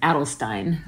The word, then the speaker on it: Adelstein: Yeah, so Garrett, and actually Garrett goes by Garrett Adelstein. – I'm sorry. – Even though it's Steen.